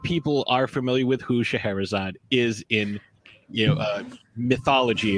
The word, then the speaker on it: Scheherazade: And for you to have a character named Scheherazade, which obviously I'm pronouncing it much different than you are. – No, you're doing great.